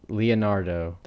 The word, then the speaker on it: Leonardo: Leonardo. Am I saying that right? – You are.